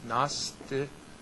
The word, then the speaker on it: Gnosticism: And remember, Gnosticism has the silent G. Gnosticism. Well, because it has a Greek origin. Gnosis, right? Gnos is knowledge.